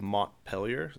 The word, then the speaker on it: Montpelier: Cody King of Montpelier, is that how you say it? – Yep.